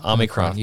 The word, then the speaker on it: Omicron: And the Omicron, am I saying it right? – Yeah, I believe so. – Omicron.